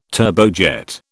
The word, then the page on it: turbojet: British English